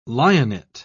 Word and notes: lionet: British English

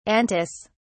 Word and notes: antis: British English

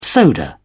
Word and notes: soda: British English

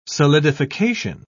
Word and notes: solidification: British English

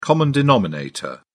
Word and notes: common denominator: British English

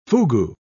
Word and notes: fugu: British English

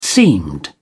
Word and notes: seamed: British English

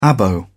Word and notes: abo: British English

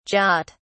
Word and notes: Jat: British English

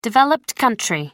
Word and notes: developed country: American English

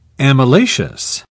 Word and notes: amylaceous: British English

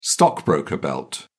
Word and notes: stockbroker belt: British English

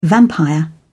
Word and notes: vampire: British English

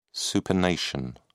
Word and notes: supination: British English

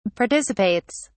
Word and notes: participates: British English

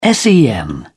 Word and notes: sen: British English

